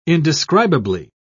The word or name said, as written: indescribably